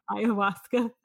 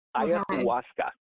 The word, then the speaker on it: Ayahuasca: So Ayahuasca. A-Y-A-H-U-A-S-E-A. Ayahuasca.